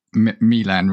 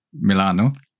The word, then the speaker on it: Milan: And even in Italy, they kind of still pronounce it Milan rather than Milano.